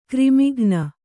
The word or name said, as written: ಘರ್ಮಕಣ